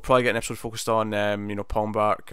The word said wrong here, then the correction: Pangborn: or what was his name? Did I say that right? right? – Pangborn. There you go.